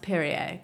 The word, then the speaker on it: Perrier: restaurant manager Emma Perrier, and it's pronounced Perrier because she is French.